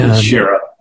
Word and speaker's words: Schara: And we have a friend here today who's been personally involved in the results of that, Scott Schara or Schara, you have to tell me if I'm pronouncing it right. – And, Schara, you did. Schara, fine.